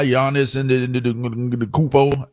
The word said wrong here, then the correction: Antetokounmpo: how you say his last name again? How y'all, anybody got it? Anybody got it proper? Say it again. – Giannis Antetokounmpo. I think I said it right.